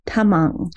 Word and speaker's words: Tamang: Welcome, and today I share with you a wonderful conversation that I had with an Ayurvedic physician named Dr. Ram Tamang, and that's T is not a T, it's a soft T, Tamang.